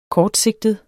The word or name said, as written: kortsigtet